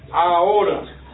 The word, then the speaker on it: ahora: Ahora. – Ahora. – Ahora. – The H doesn't sound. – Oh, okay. – Ahora. – Ahora. – That's almost saying like an hour.